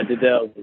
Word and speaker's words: Dedels: Yeah, it's one of those different last names, but yeah, Dedels is how you pronounce it.